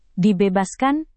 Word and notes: dibebaskan: Indonesian

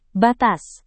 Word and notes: batas: indonesian

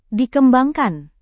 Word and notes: dikembangkan: indonesian

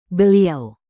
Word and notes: beliau: indonesian

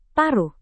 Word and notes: paruh: Indonesian

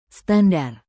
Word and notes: standar: Indonesian

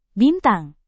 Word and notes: bintang: indonesian

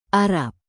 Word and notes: arab: indonesian

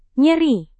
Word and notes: nyeri: Indonesian

